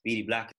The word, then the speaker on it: BDBLAQ: We stand for BD Black. It's spelled BDBLAQ, and it stands for Bermudian blood, love and artistic quality.